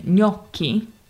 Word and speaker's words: gnocchi: Yeah, so the last syllable in gnocchi is spelled C-H-I. So the C plus H is pronounced as a hard K sound, gnocchi.